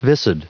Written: viscid

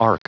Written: ark

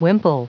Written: wimple

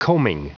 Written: coaming

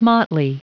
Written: motley

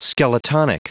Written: skeletonic

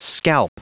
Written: scalp

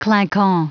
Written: clinquant

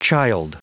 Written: childe

